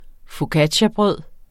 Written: focacciabrød

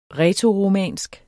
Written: rætoromansk

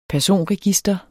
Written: personregister

